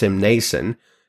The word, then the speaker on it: Mnason: By the way, one more Bible trivia question you could stump the Bible nerds with is, what chapter in the Bible has both a silent N and a silent P? Which this one does with Ptolemaeus Mnason.